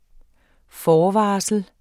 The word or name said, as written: FORVARSLET